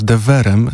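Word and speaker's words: de Veer: Ja miałem okazję z Cristobalem Tapią de Virem, bądź też de Verem, dlatego że on ma holenderskie korzenie i podobno kiedy ktoś właśnie w taki sposób wymawia jego nazwisko, to się uśmiecha.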